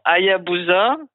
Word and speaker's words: Hayabusa: Alors, attention à la prononciation, Hayabusa, sur l'astéroïde Ryugu.